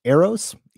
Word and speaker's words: Eros: Next question from, I believe pronounced Eros, E-R-O-S. – You got it.